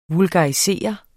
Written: VULGARISERES

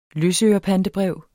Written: løsørepantebrev

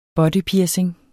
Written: bodypiercing

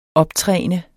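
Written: OPTRÆNET

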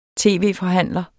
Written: tv-forhandler

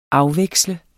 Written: afveksle

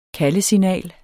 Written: kaldesignal

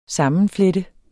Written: sammenflette

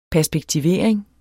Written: perspektivering

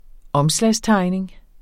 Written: omslagstegning

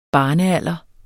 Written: barnealder

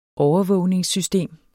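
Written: overvågningssystemer

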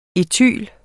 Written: ætylen

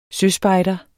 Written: søspejder

